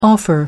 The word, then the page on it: offer: American English